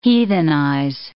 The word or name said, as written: heathenize